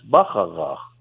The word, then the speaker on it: Bacharach: Yeah, in German it's Bacharach.